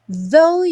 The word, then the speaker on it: though: Smile, though your heart is aching. Bom, though, although, even though, todas essas palavras que terminam com GH, o GH não vai ser pronunciado, então não se preocupe com ele.